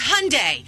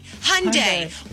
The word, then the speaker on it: Hyundai: And I said, Mom, it's Hyundai. Hyundai.